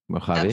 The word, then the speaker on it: Mojave: Do I pronounce this well? – Yes, Mojave. – Great. Mojave.